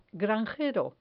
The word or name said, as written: granjero